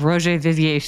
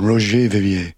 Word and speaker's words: Roger Vivier: Every time I watch it, I order another pair of Roger Vivier shoes. It's like bad for my bank account. Not doing it. – Oh, I love the pronunciation. Roger Vivier.